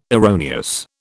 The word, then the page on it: erroneous: British English